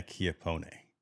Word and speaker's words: Chiappone: Ladies and gentlemen, Elena Chiappone. – Hello. – I'm saying it right, right? I'm getting it right. – Chiappone. Chiappone. It's in Italian.